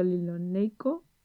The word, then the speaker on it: linoleico: Tiene hasta mil compuestos químicos distintos como aminoácidos, polisacáridos, triglicéridos, el ácido linoleico, perdón, ácido, lo he dicho bien, ácido linoleico. – Linoleico, sí.